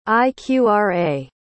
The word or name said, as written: iqra